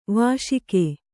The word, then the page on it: ವೈಶಾಖ: vaiśakha